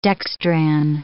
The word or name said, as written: dextran